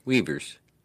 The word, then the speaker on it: Uyghurs: Uyghurs. – That's a pronunciation of that. – Really? – Yeah, that I'll do it again. – Weavers. – Okay. Yeah. There's no V there, but whatever.